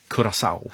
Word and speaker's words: Curaçao: Jag har fått stöd av producenten att man ska tydligen uttala det Curaçao.